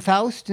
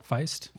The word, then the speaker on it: Feist: is it Faust? Isn't she a girl? Feist.